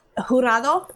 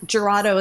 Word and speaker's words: Jurado: Well, Jurado is how you say it, but most people like Jurado is fine.